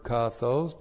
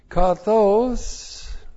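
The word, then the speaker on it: Kathos (καθώς): As God hath said, goes on, this is the adverb kathos, plus the nominative singular of theos, with the definite article, plus the verb apon, with the conjunction hati. Kathos looks like this in the Greek. K-A-T-H long O-S. Kathos.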